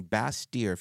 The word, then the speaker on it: Bastyr: Yeah, no, it real quick to explain, Bastyr, if you want to look it up, it's B-A-S-T-Y-R. – Correct. – It's a French pronunciation.